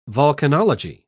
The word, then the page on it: vulcanology: British English